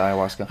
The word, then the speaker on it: ayahuasca: And his research is all about that with ayahuasca. Am I saying that right? – Ayahuasca? Mm-hmm. Yeah, ayahuasca.